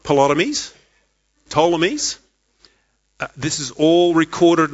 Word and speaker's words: Ptolemies: Pretty close. There's a silent P there, but you'll see it in a second. The Ptolemies reigned in the south and the Seleucids in the north.